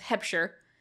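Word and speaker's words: Huebscher: The cinematography was Andrew Weibscher or Hebscher. I had to ask my friend and they said if it's German, the E is the one you pronounce, so it's Hebscher because it's H-U-E-B-S-C-H-E-R. – Yeah.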